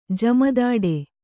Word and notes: ಜುಮ್ಮುದಟ್ಟು: Kannada

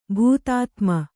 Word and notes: ಭೂತಾತ್ಮ: bhūtātma

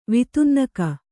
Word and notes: ವಿತುನ್ನಕ: vitunnaka

